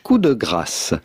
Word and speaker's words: coup de grâce: In French, coup de grâce. Coup de grâce. But in English or American, coup de grâce. Do not pronounce the P, do not say coup, but rather coup de grâce.